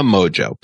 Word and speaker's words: Mongeau: Tana Mojo. And it's spelled Mongeau.